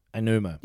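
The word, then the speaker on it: Aonuma: I'm going to butcher the name. A.G. Anuma. – Anuma. – Yeah, that's Anuma. – It. – You got it.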